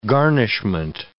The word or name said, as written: garnishment